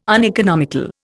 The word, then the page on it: uneconomical: Indian